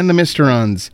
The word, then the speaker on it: Mysterons: Gerry Anderson's cult classic 1960s series Captain Scarlet and the is it pronounced Mysterons? – The Mysterons is back.